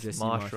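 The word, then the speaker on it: Jesse Marsch: I think Leeds look much more solid since Jesse March. Am I saying that right? Or is it Marsh? – I think it's Jesse Marsh, right? – Mm-hmm.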